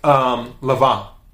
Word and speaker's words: Lanvin: The N in French is usually silent, just like Lavin, which is L-A-N-V-I-N, which they make some, I have a pair of shoes by Lavin, and when I tell you those shoes are great, they're great.